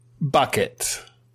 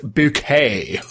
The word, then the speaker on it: Bucket: Bucket. – Accuracy. – It's pronounced bouquet.